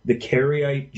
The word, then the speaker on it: Karaite: I wonder if you could tell the listeners a little bit more about, and I hope I'm pronouncing it right, the Karaite Jews. Is that how you pronounce them? – Yes, they're not.